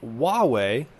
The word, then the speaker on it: Huawei: So, starting us, kicking us off here, really quick, want to want to just shout out Huawei. That's how you pronounce it. H U A.